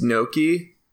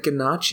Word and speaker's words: gnocchi: or like a girl that I know who whose parents mispronounced gnocchi, like the the dumpling sort of Italian thing. Uh, she's like, yeah, my parents always called it ganache. And I was like, your parents were fucking wrong.